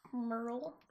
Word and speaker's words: Merrill: Merrill spelled more like Maryland. I just figure that's important. – Okay. – Because we used to live in actual Merrill, Wisconsin. – It's pronounced Merle.